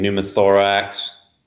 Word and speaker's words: pneumothorax: P is for a number of problems, including pneumothorax. The P is silent in that, of course.